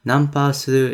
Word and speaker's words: nanpa suru: How do you say that? – Nanpa suru. – Nanpa suru. – Suru. S-U-R-U.